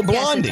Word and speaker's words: Blondie: And by the way, I realize Blondie rhymes with Gandhi in case anyone texts in. – Oh, Blondie.